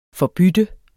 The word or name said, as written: forbytte